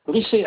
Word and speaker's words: Brisset: Brisset, à Juigné-sur-Sarthe.